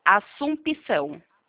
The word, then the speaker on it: Assumpção: De as aí o PE é mudo, é? – Isso, Pemudo, Assumpção. Assunção com dois Certo. – S e M.